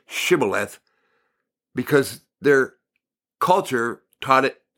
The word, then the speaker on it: Shibboleth: So they go, "If you say no," they say, "All right, say Shibboleth," S-H, Shibboleth. If he said Sibboleth, because he could not pronounce the word correctly, they seized him and killed him at the fords of Jordan.